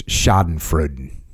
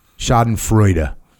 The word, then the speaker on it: Schadenfreude: So the topic that you sent me was Schadenfreude. Schadenfreude.